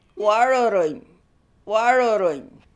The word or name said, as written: Waroorroony